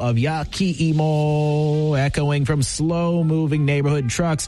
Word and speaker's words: yakiimo: Well, I'm talking about yakiimo.